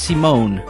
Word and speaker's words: Simon: Scaredseam is a French guy, says Rexy, as in Simon, pronounced Simone.